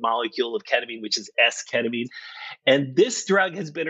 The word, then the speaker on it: esketamine: Talk to me a little bit about your research with esketamine. Is that how I'm saying it right? Esketamine? – Yeah. You're saying that exactly right.